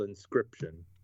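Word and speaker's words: Inscryption: It's called Inscription. – Inscription. Okay, got it. – Yeah, it's spelt weird though. It's INSCYPTION. Yeah, it's like crypt, you know, like crypt.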